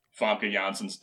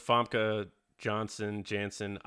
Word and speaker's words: Famke Janssen: I remember there being a lot of flack towards Famke Janssen. I'm going to say her name wrong, Mike. I don't care. – That's you don't like the soft J? – Yeah. I don't like I'm Yanni.